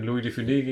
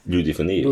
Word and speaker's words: de Funès: Louis de Funès, Louis de Funès. – De Funès also wird da tatsächlich ausgesprochen, habe ich jetzt gehört.